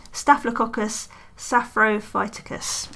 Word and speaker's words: Staphylococcus saprophyticus: It's Staphylococcus saprophyticus. I think I said that right. Staphylococcus saprophyticus. That's better.